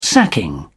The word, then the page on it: sacking: British English